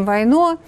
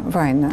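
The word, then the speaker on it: Вайно: Мы сейчас покажем вам, как это было, как они сегодня встретились с Владимиром Путиным и с товарищем Войно, который его... Война. Правильно ударение-то я ставлю? Война. – Война, наверное, финская фамилия, да.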